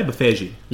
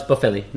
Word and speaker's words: Boffelli: Emiliano Boffelli, or how do you say Buffegi? No, it's Boffelli. – No, Boffelli. No.